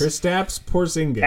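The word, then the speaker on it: Kristaps Porziņģis: How do you say his name? – It's definitely not Christian. – I know, it's going to sound like, what am I doing? Kristaps Porzingis? – Kristaps Porzingis. – Taps.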